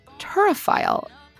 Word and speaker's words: turophile: First, did I pronounce turophile correctly? And second, what the hell does it mean? – Yes, you did.